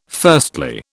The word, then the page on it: firstly: British English